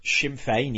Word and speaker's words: Sinn Féin: You have the Sinn Féin, right? Am I pronouncing that correctly? – Sinn Féin, yes.